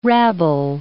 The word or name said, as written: rabble